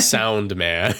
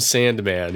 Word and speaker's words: Sound Man: Sound Man. I almost called him Sandman. He's not Sandman. He's Sound Man.